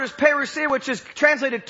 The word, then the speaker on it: parousia: The coming there, and don't get scared, is the word is parousia.